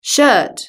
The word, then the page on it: shirt: British English